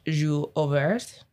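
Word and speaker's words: jour ouvert: But the origination for Juvet, is broken down version of the French Joue Ouvert. I don't know if I butchered it. I tried. – Yes, yeah, it sounded real good.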